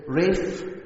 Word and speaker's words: Ranulph: The proper pronunciation of his name is Ralph. I wanted to say that because if I just said Ralph Fiennes, you would be perplexed and thought I had it wrong.